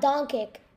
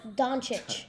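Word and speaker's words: Dončić: It's spelled Doncic. But it's not. – Eastern European. – It's not Doncic. It's Doncic.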